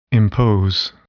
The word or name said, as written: impose